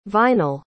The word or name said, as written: vinal